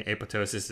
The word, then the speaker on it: apoptosis: The second P in apoptosis is silent.